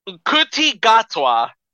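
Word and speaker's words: Ncuti Gatwa: Nkuti Gatwa.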